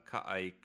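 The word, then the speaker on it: Ka'ai: The other person that took off the team is the creative director, Kai Clooney. And no, I wasn't stuttering there because the name is spelled Ka'a apostrophe AI.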